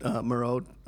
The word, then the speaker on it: Moreau: It reminded me of, because I just recently watched The Island of Dr. Monroe. – Moreau, whatever. We had this discussion, didn't we? – Yeah. And you were pronouncing it Monroe the whole time, and I didn't have the heart to stop you.